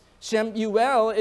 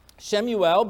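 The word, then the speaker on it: Shem Yuel (שמואל): Shem Yuel is the Hebrew pronunciation of the historical figure we refer to in English as Samuel. Samuel, Shem Yuel. Shem Yuel belonged to the Nevi'im of Israel.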